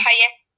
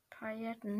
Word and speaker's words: Pailletten: Ja, aber ich mag keine Paletten tragen. – Paletten? Entschuldigung. Äh, Paletten. Äh, Pailletten. Ich kann das nicht aussprechen, aber sie hat hier Paletten geschrieben. – Echt? Nee. Doch. Pailletten.